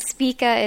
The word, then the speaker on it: Spika: So my name is Allie Spica, and Spica is S-P-I-K-A, which is kind of hard to pronounce.